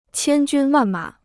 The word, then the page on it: 千军万马: qiān jūn wàn mǎ